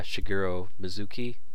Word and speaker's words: Shigeru Mizuki: I feel like this is a good transition to Nonamba by Shigeru Mizuki.